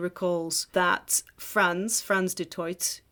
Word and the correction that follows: du Toit: or twat. You know, it's pronounced Franz du Toit.